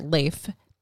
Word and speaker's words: Leif: L-E-I-F? – Yes. – I think I've always pronounced that Leif. – It is Leif.